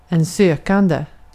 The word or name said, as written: sökande